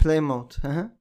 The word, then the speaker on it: Plymouth: İşte Polymont falan öyle bir tane takım vardı yanlış telaffuz etmiyorsam. – Plymouth.